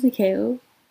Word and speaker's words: Nikao: Anyways, my name's Nikeo. Nikeo? I feel like I need to pronounce it so much, but, it's a difficult name. It's spelled Nikao. Basically say every letter, and that's how you say it.